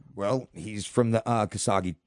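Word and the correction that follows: Arashikage: Did I say that right? – Arishkage.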